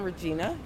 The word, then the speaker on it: Regina: Hi, I'm Regina. – All right. And could you spell that for us? – R-E-G-I-N-A.